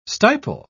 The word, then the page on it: stipel: British English